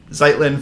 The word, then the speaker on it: Zeitlin: Zeitlin for Beast of the Southern Wild.